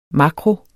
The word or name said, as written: makro